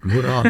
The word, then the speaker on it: Murau: Das ist ja wie Murau und Murau, also es heißt Murau, nicht?